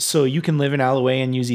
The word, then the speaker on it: Allouez: And the Z is silent. It's Alloway. – Alloway, yeah.